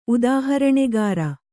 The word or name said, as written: ಉದಾಹರಣೆಗಾರ